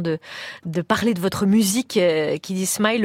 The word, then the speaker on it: Kiddy Smile: Kiddy Smile. – Ah ouais, ça se prononce comme ça, vous l'avez dit.